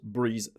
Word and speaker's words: Brise: B-R-E-E-Z-E, and this new theme is called Breeze, which is B-R-I-S-E. It's the same word, but spelled as it is spelled in French or German. It means the same thing.